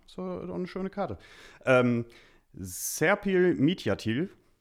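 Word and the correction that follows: Midyatli: nee, Mityatli.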